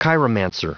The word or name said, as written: chiromancer